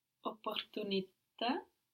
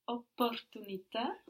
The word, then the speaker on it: opportunità: And I think you tried to pronounce, I think you did pronounce the double P there, no? – Opportunità. – So we have a double P there, even though that doesn't make a lot of difference to us in English, it's important for Italian.